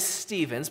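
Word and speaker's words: Stephens: See, my last name is Stevens, but it's spelt with a PH in the middle. So I often get telemarketers who call me and they go, I'm looking for a Michael Steffens or a Michael Steppens.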